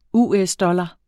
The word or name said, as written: US-dollar